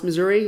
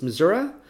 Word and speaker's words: Missouri: It was nice, but I found out that it's not pronounced Missouri, it's Missouri, and that's all I got from that job.